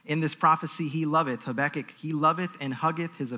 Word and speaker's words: Habakkuk: I'm going to pronounce it as Habakkuk.